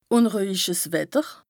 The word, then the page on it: ùnruehjiches Wetter: Bas Rhin; Strasbourg